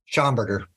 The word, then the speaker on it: Schamberger: Schamberger. – Schamberger. Okay. Yeah. I can remember that. Like Schomburg. Yep.